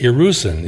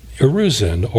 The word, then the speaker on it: Erusin: I pronounce it Erusin. It may not be exactly that, but it's spelled this way, E-R-U-S-I-N, Erusin, or engagement, or betrothal.